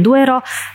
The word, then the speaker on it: Duero: Nie, na začiatku by som iba povedala, že teda ty vyslovuješ Douro, ja vyslovujem Duero. Douro sa vyslovuje po portugalsky a ja vyslovujem po španielsky Duero.